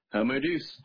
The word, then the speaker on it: Homo Deus: "And he called me yesterday and said, I'm reading this book, Homo Deus." Did I say that right? – Yeah. Homo Deus.